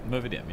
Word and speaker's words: Movidiam: Have I said it correctly? – Movidiam, yeah.